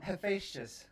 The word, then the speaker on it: Hephaestus: Hephaestus.